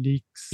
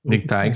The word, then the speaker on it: Lickteig: però mi ha passato solo Casey Leaks. – Lickteig.